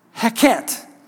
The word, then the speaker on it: Heket: It is pronounced Heket. Heket.